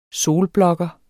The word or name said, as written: solblokker